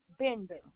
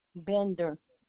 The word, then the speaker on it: Bender: Tanya Bender. My policy number is CSIL. – Ma'am. – Okay. – So your first name is Tanya? – Correct. – And your last name? – Bender.